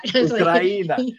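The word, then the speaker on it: Ukraina (Україна): And even my pronunciation of Ukraine in Ukrainian at the end of the song was okay. So I was going to ask you about that. – Ukraina. Yeah. And checked in with my friends and they said, nope, you got it right.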